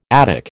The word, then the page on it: Attic: American English